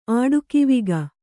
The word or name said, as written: ಆಡುಕಿವಿಗ